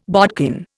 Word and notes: bodkin: Indian

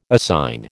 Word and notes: assign: American English

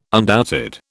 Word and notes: undoubted: British English

